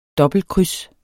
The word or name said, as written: dobbeltkryds